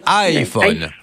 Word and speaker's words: iPhone: Le i se prononce comme Christina Cordula. Aïe. On dit iPhone.